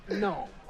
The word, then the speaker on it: gnome: The G in Gnome is silent. It's Gnome, okay? – No, it's Gnot.